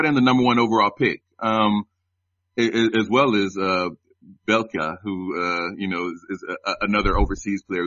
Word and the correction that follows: Bielica: his name rhymes with pizza and his name's Bielica. – Whoa. – Bielica.